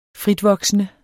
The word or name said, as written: fritvoksende